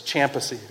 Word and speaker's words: Kyampisi: And so I know that's spelled one way, it's pronounced different, but it's Kyampisi, right?